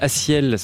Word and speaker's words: A6EL: Il s'appelle Aciel, ça s'écrit A6, le chiffre EL, A6EL, extrait d'un album qui s'appelle, c'est pas un album, c'est un single qui s'appelle Soleil.